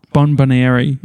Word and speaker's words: bonbonniere: It is spelled B-O-N-B-O-N-N-I-E-R-E. Bonbonieri.